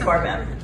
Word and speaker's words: Barbeau: My last name is not spelled the way it's pronounced, so it's barbecue, barely boobs. I've gotten it all growing up, and it's Barbeau. So I want to tell you guys a little story about my mother.